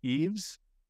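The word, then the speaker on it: Yves: I believe you pronounce his name Eves. I'm almost positive Eves Nil.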